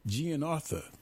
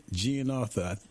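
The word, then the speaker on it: GianArthur: I-69, Roman Gianartha. That's how you pronounce his last name, Roman Gianartha. I'm pretty sure I'm saying that right.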